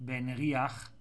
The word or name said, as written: Benriach